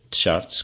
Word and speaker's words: charts: É preciso ter cuidado com a pronúncia dessa palavra, porque se for tabelas, charts, tem que se pronunciar charts, com CH, porque se pronunciar charts... pode ser confundido com a palavra inglesa SHARTS, charts, que quer dizer peidos com o molho, que é a contração informal da palavra fart com a palavra...